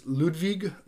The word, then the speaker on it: Ludwig: In German, that is Ludwig von Beethoven. – Correct. – Uh, again, before I spell it, listen for the W and the V. So our W's in German are V.